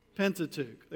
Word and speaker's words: Pentateuch: One, two, three, Pentateuch.